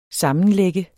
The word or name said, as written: sammenlægge